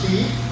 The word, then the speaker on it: bede: Even the word be for the rosary, to count all of the 150 prayers of the rosary, the word be comes from an old English word pronounced be, but spelled B-E-D-E.